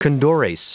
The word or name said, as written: condores